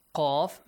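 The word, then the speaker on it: Qaf (ق): Qaf, make a heavy sound.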